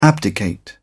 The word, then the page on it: abdicate: British English